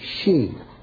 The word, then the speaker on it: Sidhe: It's the way you pronounce S-I-D-H-E, She.